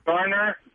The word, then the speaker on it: Garner: Now, who is Jennifer Gardner? I'm not familiar. – Garner. – Oh, yes. – Garner. Bye! – I've never liked that distinction. If you're going to pronounce the name correctly, pronounce it correctly. If we were going to clean your yard and do your lawn, we'd be Gardner.